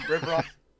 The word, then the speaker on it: Riveron: Yeah, that's a way to say it. Um, Riveron. no, I've, I've gotten Riveran, I've gotten Riveron, I've got Rivero before.